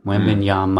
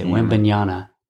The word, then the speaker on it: Wembanyama: The last consonant of his name is an M. Wembanyama. Mm. And they say Wembanyama.